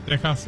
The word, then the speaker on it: Texas: Saludos al Valle de Texas, y la X se pronuncia igual que México.